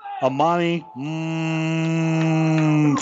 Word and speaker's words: Amani: They, they said you got to draw out the M. That's the way he pronounces his name. Amani mm, Finger.